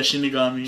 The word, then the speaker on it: Shinigami: Yeah, Shinigami.